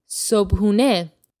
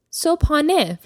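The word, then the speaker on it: sobhune (صبحانه): In Persian, breakfast is called sobhune. – Sobhune. – You'll also hear this pronounced sobhane from time to time.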